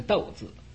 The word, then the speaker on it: 读: 没有读过书的人呢，这个读字就会读成呢读字，啊，这个应该读成一个斗字。